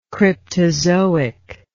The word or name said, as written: cryptozoic